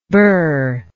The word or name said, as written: buhr